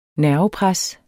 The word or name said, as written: nervepres